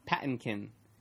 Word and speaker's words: Patinkin: I mean, you have Mandy Patinkin. Wait, how do you say his last name? – Who? – The guy who played Indigo. – Patinkin.